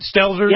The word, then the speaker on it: Stelzer: Is that how you say it, Stelzer? – Yep, that's right.